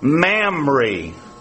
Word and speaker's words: Mamre: "And came and dwelt in the plain of Mamre." I guess I can say this in mixed company. That rhymes with a gland that females have, okay?